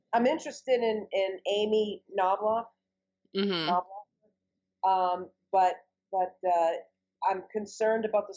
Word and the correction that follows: Klobuchar: Amy Klobuchar? – Yeah, that's it.